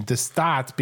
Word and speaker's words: De Staat: By the way, I meant to mention De Staat, because most people probably don't speak Dutch. It's spelled D-E-S-T-A-A-T.